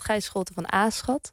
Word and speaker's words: Gijs Scholte van Aschat: Verder horen we dus inderdaad Gijs Scholte van Aschat. Zo spreek je dat blijkbaar uit.